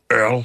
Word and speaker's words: URL: Og hvorfor er det så, at en hund, den hedder URL, eller Earl, som man udtaler det?